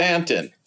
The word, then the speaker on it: Staunton: So, you're close enough to Staunton. Stanton, how do you pronounce it? – Stanton.